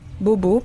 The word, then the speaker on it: Bohbot: Je Euh, ensuite, je voulais parler de Frank euh, Franck Boho, oh, Bobo, pardon. Ça s'écrit B-O-H-B-O-T.